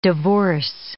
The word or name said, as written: divorce